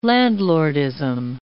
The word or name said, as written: landlordism